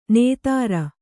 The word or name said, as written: ನೆತ್ತರ